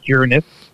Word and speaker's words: Uranus: When they say lineup, they even included Uranus and Neptune, and knows how I pronounce Uranus, not the other way. It's like you're a good person, not stress on the A.